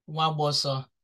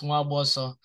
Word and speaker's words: Nwagboso: The N W sounds like a letter, so the N is silent. So it's Mwaboso.